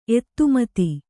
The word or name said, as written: ಎತ್ತುಮತಿ